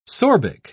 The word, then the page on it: sorbic: British English